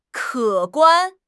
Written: 可观

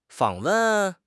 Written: 访问

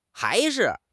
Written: hai2 shi5

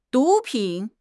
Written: 毒品

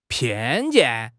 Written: 偏见

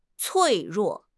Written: 脆弱

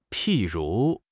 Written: 譬如

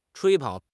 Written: 吹捧

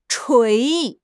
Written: 锤

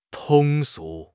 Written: 通俗

